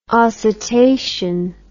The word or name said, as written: oscitation